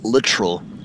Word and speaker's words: Littrell: Hey, man, it's pronounced literal. So the accent is on the front. It's a little more English versus French, because everybody goes Littrell. That's easier to pronounce, but we pronounce it literal.